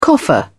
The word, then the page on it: coffer: British English